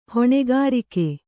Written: ಹೊಣೆಗಾರಿಕೆ

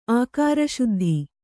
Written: ಆಕಾರಶುದ್ಧಿ

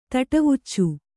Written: ತಟವುಚ್ಚು

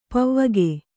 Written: ಪೌವಗೆ

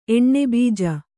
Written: ಎಣ್ಣೆಬೀಜ

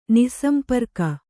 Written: ನಿಃಸಂಪರ್ಕ

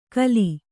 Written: ಖುಲ್ಲ

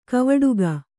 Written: ಕವಡುಗ

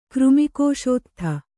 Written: ಕೃಮಿಕೋಶೋತ್ಥ

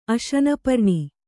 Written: ಅಶನಪರ್ಣಿ

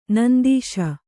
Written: ನೊಂದಿಸು

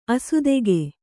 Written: ಆಸ್ತಿಕ

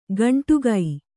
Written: ಗಂಟುಗೈ

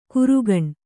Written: ಕರಗಾಣ

